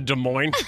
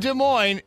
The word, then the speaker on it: Des Moines: Violetta moves to Des Moines. She lasts 30 days. – I love corn. – 30 days. – They got a good slogan in Des Moines. It's like where the S is silent, but the nightlife isn't, or something like that.